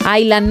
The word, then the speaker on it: island: En Island no pronuncian la S. Y yo diría que en la música folk no dicen folk.